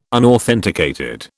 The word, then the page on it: unauthenticated: British English